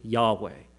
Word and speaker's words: Yahweh: You see, the name I am giving you now is this YHWH that I'll just simply pronounce as Yahweh.